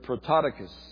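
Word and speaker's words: Prototokos (πρωτότοκος): This word firstborn is the Greek word prototokos. I know. How do you spell that? P-R-O. Okay. P-R-O.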